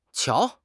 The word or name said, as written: qiao2